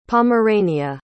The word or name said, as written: pomerania